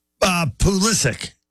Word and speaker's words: Pulisic: Pulisic. Right? Hard C, not a ch, but a...